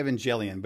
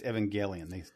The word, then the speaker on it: Evangelion: I keep wanting to say Evangelion, but it's Evangelion. They call it. – Yeah, I often say Evangelion too, but it's like Evangelion. Yep. It's closer to the Japanese pronunciation. – It's a hard G is how they pronounce it.